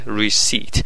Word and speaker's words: receipt: پی تلفظ نمیشه receipt